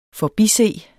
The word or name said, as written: forbise